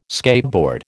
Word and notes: skateboard: American English

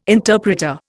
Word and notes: interpreter: Indian

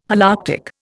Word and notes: Holarctic: Indian

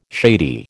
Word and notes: shady: American English

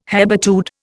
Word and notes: hebetude: Indian

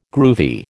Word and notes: groovy: American English

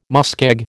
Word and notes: muskeg: American English

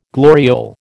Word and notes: gloriole: American English

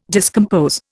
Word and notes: discompose: Indian

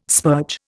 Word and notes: spurge: Indian

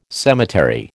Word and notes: cemetery: American English

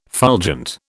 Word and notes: fulgent: British English

